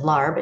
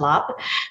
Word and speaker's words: larb: Also, many pronounce it as larb if you see it on Thai menu, but it's pronounced lop.